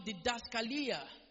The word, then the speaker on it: didaskalia: When you hear doctrine, the word doctrine is the Greek word didaskalia. The word doctrine is the Greek word didaskalia. I'll spell that D-I-D-A-S-K-A-L-I-A. Didaskalia. D-I-D-A-S-K-A-L-I-A.